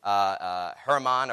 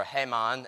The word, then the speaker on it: Heman: Another way of pronouncing the other ones, it's Herman or Heman, and then Ethan, that's how you say it. But I'm going to say Heman and Ethan, that's how I say it.